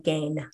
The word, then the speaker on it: Gein: The number one name that I came across was Ed Gein or Gein or Gine or Gein. I don't know. – I think it's pronounced Ed Gein.